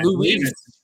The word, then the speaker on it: Luis: But Lewis, and I said, and it's Luis. So between the two of us, we've offended his entire family.